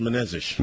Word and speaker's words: Menezes: Jean-Charles Menezes. – Menezes,